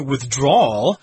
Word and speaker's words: withdrawal: Or, whereas in this case, it's a noun here, withdrawal. Withdrawal. That's kind of hard to pronounce. The W and the L kind of merge together.